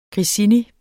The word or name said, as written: grissini